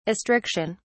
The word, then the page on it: astriction: British English